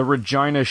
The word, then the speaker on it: Regina: The Regina shout-out was mentioned. – Yeah, that's why Regina rhymes with fun.